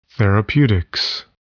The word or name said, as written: therapeutics